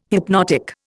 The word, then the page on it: hypnotic: Indian